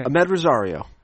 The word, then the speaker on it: Amed Rosario: Even the first shortstop on the list, Amed Rosario. Am I saying that correctly? You say it. – Yep. – Okay. – Amed Rosario.